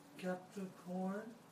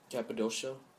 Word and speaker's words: Cappadocia: Capricorn. – Cappadocia.